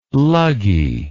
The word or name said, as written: luggie